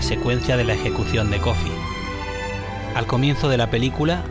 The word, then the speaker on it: Coffey: Pero si hay alguien que destaque sobre el resto es el inolvidable Michael Clarke Duncan en la piel del preso John Coffey, como El Café pero se escribe diferente.